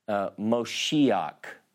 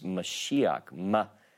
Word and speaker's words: Mashiach: People say, I hear people sometimes pronounce Moshiach, like come Moshiach. Well, that's not a word in Hebrew. It's Mashiach. See, Moshiach is a different word.